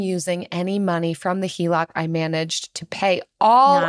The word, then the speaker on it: HELOC: I recently got approved for a HELOC. Is that how you pronounce it? – That is how you pronounce it.